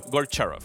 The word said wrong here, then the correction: Goncharov: Este, mi pronunciación no. este, es Goncharov.